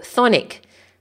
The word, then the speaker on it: chthonic: Okay, it's thonian or thonic. Now let me spell it for you. Thonian is actually C-H-T-H-O-N-I-A-N, or thonic is C-H-T-H-O-N-I-C.